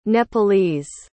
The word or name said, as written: nepalese